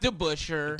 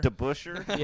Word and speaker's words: DeBusschere: It's pronounced DeBusschere. – DeBusschere? – Yeah.